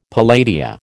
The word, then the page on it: palladia: American English